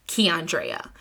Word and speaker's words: Keandrea: Keandrea. Now, phonetically, it's spelled exactly how it's pronounced. Key on Drea.